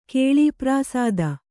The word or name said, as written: ಕೇಳೀಪ್ರಾಸಾದ